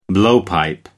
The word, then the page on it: blowpipe: American English